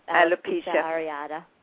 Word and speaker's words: alopecia areata: alopecia, how do you say that again? – Yep, alopecia areata.